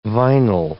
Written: vinyl

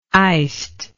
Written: iced

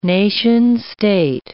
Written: nation-state

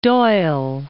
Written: Doyle

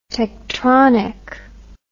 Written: techtronic

